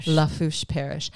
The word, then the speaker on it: Lafourche: A friend of mine from NOLA, again, helped me with the pronunciation of that, as well as the next thing on the newspaper here. Lafourche Parish.